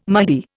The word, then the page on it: Mahdi: Indian